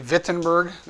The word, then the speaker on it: Wittenberg: It's actually Wittenberg. That's kind of the way it's pronounced.